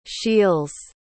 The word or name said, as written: Shiels